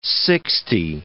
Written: sixty

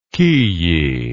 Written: kiyi

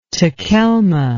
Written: takelma

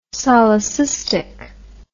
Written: solecistic

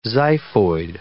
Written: xiphoid

